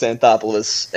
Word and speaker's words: Anthopoulos: Is it Anthopoulos or what? Alex. – Yeah, Alex Anthopoulos.